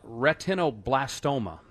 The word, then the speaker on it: retinoblastoma: Tell me about retinoblastoma. And I hope I even said it right. – You did.